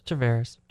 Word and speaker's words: Tavares: Did I say it right? Tavares? – Tavares. – Tavares. Okay, Tavares.